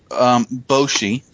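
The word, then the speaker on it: Boche: It's actually pronounced Boshi, so you were right.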